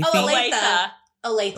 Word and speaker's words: Olathe: Olathe.